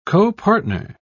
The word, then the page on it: copartner: British English